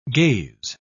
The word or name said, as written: gaze